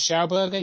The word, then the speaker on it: Schauberger: That leads on to, I wanted to just briefly mention, is it Schauberger? Is that how you pronounce his name? – Victor Schauberger, yeah.